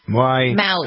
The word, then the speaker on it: Maui: He says Moai changed his life. Is that how you pronounce it? – Maui. – How do you pronounce it? – Maui. The island.